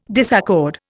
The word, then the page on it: disaccord: Indian